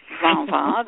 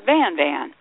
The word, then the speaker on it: Van Van: But it's called Van Van because American speakers didn't pronounce it Vava. They pronounce it Van Van.